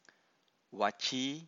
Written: vaci

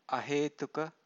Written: ahetuka

